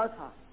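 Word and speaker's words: Ahaz: which has gone down in the sundial of, how you pronounce it, Azah? – Yeah, Azah.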